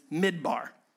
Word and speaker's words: midbar: Now, let's turn our attention, number two, to the Hebrew word for wilderness, which is midbar. Midbar, and if you're taking notes, it's spelled exactly the way that it sounds, M-I-D-B-A-R.